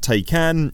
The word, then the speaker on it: Taycan: Tay as in necktie, con as in con artist. It's spelt Taycan, it's pronounced Taycan.